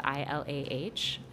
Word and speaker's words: Ilah: My name is spelled I L A H, and so Ilah is all over the building.